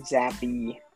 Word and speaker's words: Bailey Zappe: And then he gets injured, so Bailey Zappe. Is that how you say it? Or Zappe? – Zappe. – Oh, Jesus Christ.